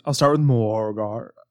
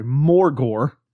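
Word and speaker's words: Morgawr: So I'll start with Morgar. Or sorry, Morgor, as I heard him pronounce it.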